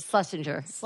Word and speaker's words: Schlesinger: But Eliza, how do you say her last name? Schlesinger? – Eliza Schlesinger. – Oh, that's how you say it? – Yeah.